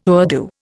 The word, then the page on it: Urdu: Indian